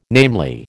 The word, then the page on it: namely: American English